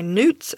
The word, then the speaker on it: newt: Americans really like to straighten out those U sounds, but I'm doing my best to pronounce it newt, because that's how most people in the world pronounce it in English.